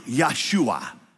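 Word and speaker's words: Yahshua (יהושע): In Hebrew, Yahshua. That's how you say Joshua in Hebrew.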